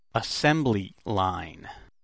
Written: assembly line

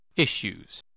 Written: issues

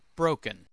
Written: broken

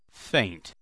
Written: faint

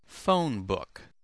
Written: phonebook

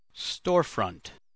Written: storefront

